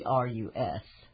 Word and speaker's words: Cerberus: I'm not sure how you pronounce that. It's Cerberus.